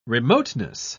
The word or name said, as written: remoteness